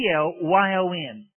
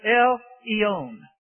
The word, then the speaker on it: El Elyon: It is spelled this way, El-Elyon. If you have a Scofield Bible, you will notice the note in Daniel chapter 4. That particular name of God, El Elyon, or sometimes pronounced El Eon, El Elyon means this, the Most High.